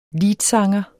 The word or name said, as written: liedsanger